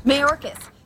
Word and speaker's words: Mayorkas: Mayorkas, stop acting like a dictator and try acting like you give a darn about the safety... – With the accent on the first syllable.